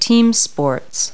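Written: team sports

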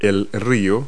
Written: river

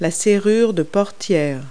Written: door lock

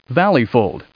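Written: valley fold

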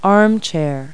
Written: armchair

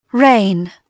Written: rain

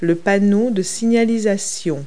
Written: road sign

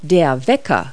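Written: alarm clock

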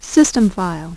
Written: System file

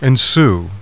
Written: ensue

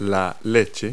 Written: milk

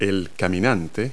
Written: hiker

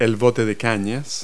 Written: reed boat

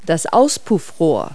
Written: muffler

